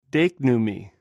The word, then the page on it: deiknu: Greek